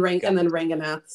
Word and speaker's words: Ranganath: And then Ranganath sort of phonetically.